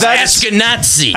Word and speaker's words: Ashkenazi: Ashkenazi, sir. It's pronounced Ashkenazi.